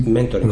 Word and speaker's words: Mentoring: no sé si se pronuncia exactamente así. – Mentoring, luego. Sí, sí, sí.